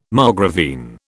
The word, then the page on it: margravine: British English